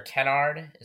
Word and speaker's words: Kennard: So Luke Kennard, or Kennard, is that how you say it? – Yes, and that is correct.